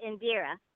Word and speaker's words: Indira: Indira. Have you heard of, do you know about Indira Gandhi? – Yes. Yeah, Indira Gandhi. – That's how you pronounce it, Indira.